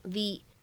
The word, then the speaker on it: the: 这样的三个单词当中呢，首先我们会关注到啊，末尾这样的一个定冠词，它此时呢应该是一个重读的发音，所以呢它不能读成 the，应该读成 the。